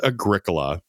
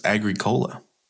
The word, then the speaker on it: Agricola: Number 9 is Agricola. – That's not how you pronounce it. It's Agricola.